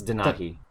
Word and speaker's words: Denahi: That's Denahi. The, the middlest brother. um, yes, yes. Kenai, Denahi, and Sitka, the McElroy brothers. – The movie starts uh, with Denahi in the future talking about the past, talking about this stuff.